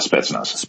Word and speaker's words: Spetsnaz: Is it Spetsnaz? – Yeah, Spetsnaz.